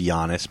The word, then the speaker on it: Giannis: It's actually pronounced Giannis, but okay.